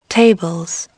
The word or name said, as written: tables